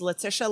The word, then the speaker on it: Letitia: My name is Letitia, like Patricia, because that's the way you pronounce it.